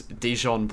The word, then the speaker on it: Dijon: Is it pronounced Dion? – Yeah. It is. – Is it the French pronunciation? – Yeah. My name is Dijon Bluru.